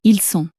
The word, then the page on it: ils sont: French